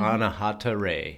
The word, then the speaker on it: Anahata Ray: Anahata Ray.